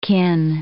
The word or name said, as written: kin